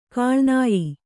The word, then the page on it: ಕೀಳ್ನಾಯ್: kīḷnāy